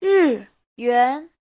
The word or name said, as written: ri4yuan2